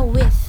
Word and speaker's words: with: Oke, jadi cara baca TH itu misalnya nih ada kata with. Nah, cara bacanya tuh enggak kayak with, itu enggak. Jadi yang benar, cara baca yang benar itu adalah with. – Okay. Ya, yang tadi jelasin. Nah, kalau kita bisa tahu kalau pronunciation atau pelafalan kita benar itu itu dari kalau misalnya keluar angin dari mulut kita with.